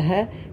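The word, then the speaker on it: Yod he (ה): So that's the first he. Is that how you say it? – Yod. Yod he. he.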